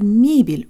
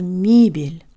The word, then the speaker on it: мебель: In the US, some people say eggs, but other people say eggs. It's this kind of high E sound that we need in the first syllable of мебель.